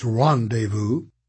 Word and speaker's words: rendezvous: She pronounced the word as if it rhymed with Ben Says Mouse. – It's French, said Sticky. It's pronounced rendezvous.